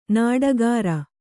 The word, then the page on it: ನೀಟುಗಾರ: nīṭugāra